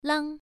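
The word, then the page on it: lang: tone 1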